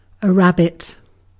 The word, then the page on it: kani: [ˈkɑni]